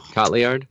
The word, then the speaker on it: Cotillard: That's Cotillard.